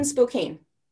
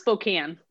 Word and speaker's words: Spokane: So you grew up in Spokane. – Yeah. It's pronounced Spokane. – Spokane. Sorry.